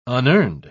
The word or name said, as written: unearned